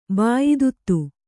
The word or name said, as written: ಬಾಯಿದುತ್ತು